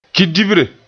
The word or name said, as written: kidibre